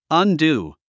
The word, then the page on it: undo: ʌ`ndú; アンドゥ